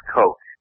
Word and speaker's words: Koch: And they've received a ton of money from this organization called, it's pronounced Koch. – Yeah, it's spelled K-O-C-H, but it's pronounced Koch.